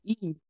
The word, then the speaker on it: Eid: I said Eid. E-I-D. It's a religious holiday. E? – Oh my. – No. E-I-D. And then some kids also try to pronounce it for me so you can hear it clearer.